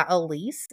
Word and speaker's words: Alyse: I am available on Facebook at Fairy Tale Journeys by Alyse, and my name is spelled A-L-Y-S-E.